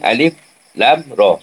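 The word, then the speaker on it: alif, lam, roh (الر): Maka cara membacanya alif, lam, roh.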